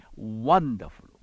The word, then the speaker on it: Wonderful: 少しオーバーに発音する必要がありますね。 Wonderful、 Wonderful、口を尖らして Wonderful Counselor。